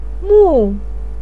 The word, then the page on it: mu4: pinyin